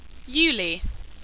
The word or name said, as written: yullie